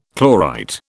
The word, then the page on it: chlorite: British English